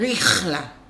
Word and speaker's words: Rihla: Rihla. Yes. So, isn't it like in Arabic, you pronounce the H with a little like... – Rihla.